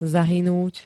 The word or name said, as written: zahynúť